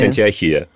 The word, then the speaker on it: Антиохия: Ну, произносят это так и так. Все-таки, если мы говорим Александрия, то... – Логично произносить логично Антиохия. Говорить Антиохия. Вот.